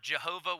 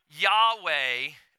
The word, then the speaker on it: Yahweh: Now, some people will pronounce this word Jehovah. We actually know that is a mispronunciation of this. So, like, you know, no offense to Jehovah's Witness or whatever, but like the whole thing is based on a mispronunciation. Or some people pronounce this Yahweh, um, which we don't know if that's a correct pronunciation or not.